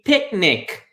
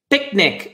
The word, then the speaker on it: picnic: Prepare a picnic. Aqui você não fala pic-nic, né? Você vai falar picnic, picnic. Esse C praticamente não é pronunciado, é picnic, picnic.